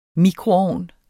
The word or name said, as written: mikroovn